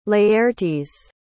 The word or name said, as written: Laertes